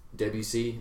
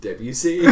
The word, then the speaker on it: Debussy: Debussy.